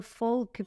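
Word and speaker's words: Volk: Eu falo Folk porque em alemão o V é Folk.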